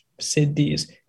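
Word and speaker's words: siddhis: It's remote viewing, your intuitive insight, telepathic communication, all of these different cities, so to speak, S-I-D-D-I-H, cities. It's spelled differently. It's not S-I-T-Y. It's like the Sanskrit spelling of it, S-I-D-D-I-H-S, I think.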